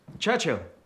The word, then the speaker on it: Churchill: Churchill? That's how you say it. All together like that. Churchill. Oh, well, that explains a lot. You see, I'd heard church and hill, but couldn't find either.